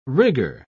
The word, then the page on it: rigger: British English